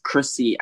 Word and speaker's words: Chrissy: My Instagram is Chrissy Ajisafi, which is Chrissy with two S's, C-H-R-I-S-S-Y.